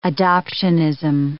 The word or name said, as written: adoptionism